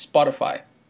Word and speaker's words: Spotify: Que eu nem sei se essa é a pronúncia correta em português, mas Spotify. – É, isso mesmo.